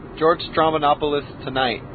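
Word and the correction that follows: Stroumboulopoulos: Strombolopoulos. – You know, I put an N in there. I went Stramanopoulos. No, Strombolop. I still need some work.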